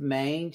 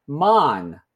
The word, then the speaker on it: Maine: So, like, she says it's Portsmouth. And then they're like, where? She's like, in Mon. And they're like, it's Maine. But that's cute and all, but it's a little confusing.